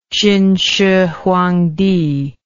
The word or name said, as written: chin shih huang ti